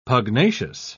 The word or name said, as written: pugnacious